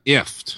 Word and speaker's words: IFTTT: IFT. – Yeah. – Well, how is that spelled? – IFTTT. They say it's pronounced like gift without the G.